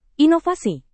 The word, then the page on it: inovasi: indonesian